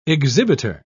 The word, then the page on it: exhibiter: British English